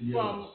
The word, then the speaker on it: gyros: It's Gyros. That's the correct pronunciation.